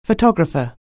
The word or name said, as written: photographer